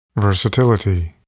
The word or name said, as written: versatility